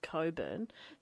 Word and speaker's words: Cockburn: It's spelled Cockburn, but it's pronounced Coburn.